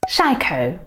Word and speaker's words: psycho: Шайко. Только не психуйте, но оно не произносится как психа или псича и еще другие варианты, а шайко.